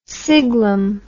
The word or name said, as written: siglum